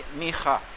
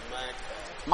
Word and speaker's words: Micah: And how do you say that in English? Micha. Micah? Micah? Micah? – Yeah, Micah.